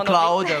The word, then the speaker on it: cloud: Un cloud, un cloud. – Exactement, cloud, voilà.